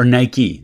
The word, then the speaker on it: Nike: Or Nike is how you say it.